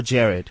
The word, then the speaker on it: Jerrard: Is it Jerrard or Jerrard? – Jerrard. – Jerrard Peace. You. Jerrard Peace. – You're one of the few people that get his name right, actually. – Is it? – Everyone says Gerrard, Mr. G, and all this.